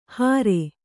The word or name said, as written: ಹರೆ